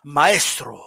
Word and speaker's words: Maestro: But you gotta pronounce it. You know, you gotta pronounce and enunciate it right for Maestro, you know. Maestro. – Maestro. – You know what I mean? Like if you say Maestro, like what the fuck is Maestro? – Whoa.